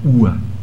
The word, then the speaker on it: Uhr: Várias pátrias, mas apenas uma coisa que em alemão se escreve UHUR. UHR, chama UA.